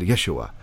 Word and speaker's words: Yeshua: That's how they said Yeshua.